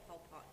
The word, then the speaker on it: Pol Pot: Yeah, Pol Pot. P-O-L. – P-O-T. – P-O-T, yeah.